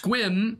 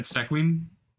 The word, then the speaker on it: Sequim: S- squim, squim was uh was one that I was corrected on. Uh, yeah, I would have said sequim.